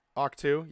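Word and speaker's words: Ahch-To: And Arc 2. Is that right? – At the very end. – Octu, yeah, that's the one.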